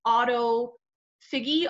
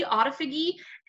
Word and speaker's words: autophagy: It's autophagy, autophagy.